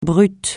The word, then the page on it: brut: francais